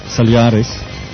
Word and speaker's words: Solaris: The Slacker's Saloon is called Solaris, and that is not the ITC firm, but you should pronounce it in Russian, Solaris,